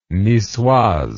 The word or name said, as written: nicoise